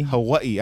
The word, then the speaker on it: Hawaii: And is that how it's pronounced? Is it Hawaii? – Hawaii. I mean, the natives say Hawaii.